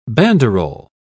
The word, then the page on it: banderol: British English